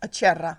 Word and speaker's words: Acerra: How do I say your last name in Italian? – Acerra. – Acerra. See, I was doing it in Spanish, which does not do it justice.